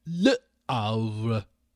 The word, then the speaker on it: Le Havre: In French, in France, we would say it as Le Havre. Of course, the H is silent in French, Le Havre.